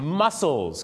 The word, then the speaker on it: mussels: mussels ครับที่แปลว่ากล้ามเนื้อเหรอครับที่จริงออกเสียงเหมือนกันแต่สะกดกันไม่เหมือนกันนะครับ